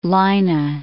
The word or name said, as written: Leine